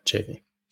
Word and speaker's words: Chaffee: You can just go to his – if you basically just type in Anthony Chaffee, did I say it right, Chaffee? – Yeah, Chaffee.